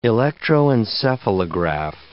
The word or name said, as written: electroencephalograph